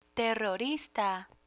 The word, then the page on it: terrorista: spanish